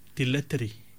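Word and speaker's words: dilatory: Well, the word that we were looking at today, Morana, is the word dilettery. The word dilettery. Dilettery, it's an, well, it's eight letters. It's spelled D-I-L-A-T-O-R-Y. D-I-L-A-T-O-R-Y.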